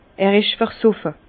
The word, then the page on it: er isch versoffe: Bas-Rhin; Herrlisheim